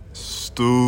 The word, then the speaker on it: Stoops: Let me make sure I say his name right, Sooner Nation. I'm going to say it the way y'all say it. Stoops.